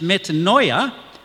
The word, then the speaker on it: metanoia: And in the Greek New Testament, there's a word which is pronounced metanoia, and in English, it's translated as repentance.